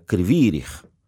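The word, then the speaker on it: Kryvyi Rih: se pronuncia Krivirich, quiere decir cuerno chueco, cuerno caído, el cuerno donde se bebe el vino.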